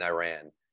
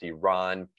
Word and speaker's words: Iran: Tyler kept saying Iran. And the reporter says, you keep mispronouncing our country's name. It's pronounced Iran.